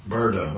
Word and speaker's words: Brda: with Iza Nine Berda. I didn't fuck that up. There's no vowel. – Oh. – B-R-D-A. How would you say it? – Berda.